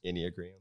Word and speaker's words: Enneagram: Enneagram. – Okay, see, Enneagram.